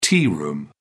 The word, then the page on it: tea room: British English